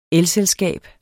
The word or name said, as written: elselskab